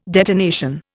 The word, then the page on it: detonation: Indian